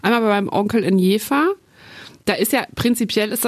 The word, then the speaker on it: Jever: Ich dachte immer, das spricht man. – Jever. – Ja. – Ja, ja, das Bier heißt, also man ist sich unschlüssig, die Stadt heißt aber Jever.